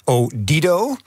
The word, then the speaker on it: Odido: Zeker voor iedereen die met marketing en reclame bezig is, dat is de naamswisseling van T-Mobile in Odido.